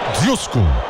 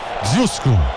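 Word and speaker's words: Ožujsko: Eu tô tentando ver aqui como é que eu falo. É Djusko. Se escreve DZ com aquele circunflexo ao contrário, UJSKO. Djusko. Rapaz, e não é que é boa a cerveja dos caras?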